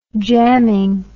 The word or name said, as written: jamming